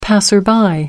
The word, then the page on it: passer-by: American English